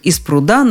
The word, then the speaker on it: пруда: Ударение во всех падежах на конце слова. Пруд из пруда на пруду, в пруду, с прудом, о пруде. Ну и, естественно, пруды, прудов, прудами.